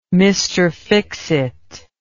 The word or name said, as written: mr fixit